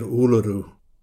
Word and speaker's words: Uluru: I said white fellas like me think we're doing the right thing by calling it Uluru, and I was careful to pronounce it correctly, stress on the first syllable, tongue towards hard palate for the retroflex R.